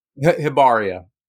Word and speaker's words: Hibernia: So with Tales of Hibernia, I want to, am I saying that right? Hibernia? – Hibernia. – Hibernia. That's okay.